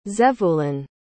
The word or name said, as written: zevulun